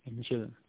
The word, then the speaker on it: angel: Angel tulisannya angel ya. Tapi bacanya angel.